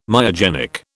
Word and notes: myogenic: British English

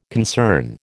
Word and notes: concern: American English

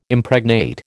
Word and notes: Impregnate: American English

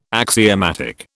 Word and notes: axiomatic: British English

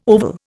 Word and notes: oval: Indian

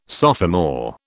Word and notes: sophomore: British English

